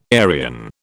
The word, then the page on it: Aryan: British English